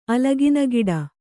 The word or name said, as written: ಅಲಗಿನಗಿಡ